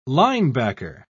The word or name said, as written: linebacker